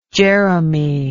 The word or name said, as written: Jeremy